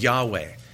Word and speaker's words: Yahweh: It's YHWH, probably the correct pronunciation of God's name is Yahweh.